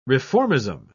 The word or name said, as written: reformism